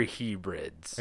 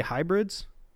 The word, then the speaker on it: Hebrides: So the Minch is a strait that separates the northwestern highlands of Scotland and the northern inner Hebrides from the northern outer Hebrides. – Are you trying to say hybrids? – No, it's H-E-B-R-I-D-E-S. Hebrides.